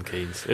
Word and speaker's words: Keynes: Because I went to Tom being British – a town called Milton Keynes Keynes, I think is how they say it. – Keynes.